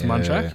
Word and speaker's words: Munchak: It's Mike Munchak, isn't it? I always get their coach's name wrong. So the Munchak or Malarkey or something like that. – It's more Munchak, I think. – It's Munchak, yeah.